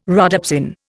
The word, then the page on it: rhodopsin: Indian